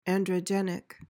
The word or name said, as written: androgenic